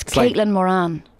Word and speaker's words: Caitlin Moran: I was calling her... – Kathleen Moran. – Yeah, because we say Moran. – Yeah, but... – Kathleen, but it's Caitlin Moran.